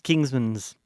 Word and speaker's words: Kingsman: Like pronounce it like an yeah. E-N and so not an A-N. The Kings Kingsman's.